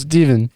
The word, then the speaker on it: Steven: Yeah, name is Steven. Rhymes with even.